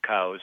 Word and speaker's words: Coues: and then some places you'll only find coos whitetail. And we should pronounce it cows, C-O-U-E-S.